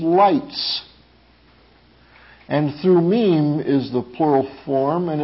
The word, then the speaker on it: Thummim: Urim in Hebrew and Thummim is literally how it's pronounced.